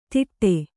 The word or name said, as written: ತಿಟ್ಟೆ